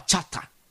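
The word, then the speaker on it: chata (חטא): Lakini katika lugha halisi ile ya awali ya Kiebrania, anazungumza, anasema hivi, neno hili makosa amelitamka, au vocabulary hii, msamiati unatamkwa kama chata.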